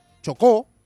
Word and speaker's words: Chocó: El sismo registrado a las 7:34 local, 8:34 en Chile, con epicentro en San José del Palmar, del departamento del Chocó, acento en la última O,